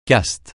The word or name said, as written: caste